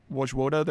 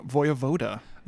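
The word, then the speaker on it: Wojewoda: The Wojvoda that we've always said? – Yeah, Michael Wojvoda. – He pronounces his name Vojvoda. – Vojvoda! – Yeah, that's the Polish pronunciation.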